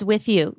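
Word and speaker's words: Wiithyu: So With You, is that pronounced correctly first? – It's With You. Yes, with it's you. Spelled a little differently, though. So it's a little unique. It's W-I-I-T-H-Y-U.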